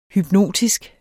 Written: hypnotisk

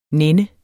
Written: nænne

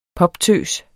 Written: poptøs

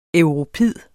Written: europid

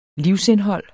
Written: livsindhold

